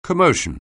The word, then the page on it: commotion: anglais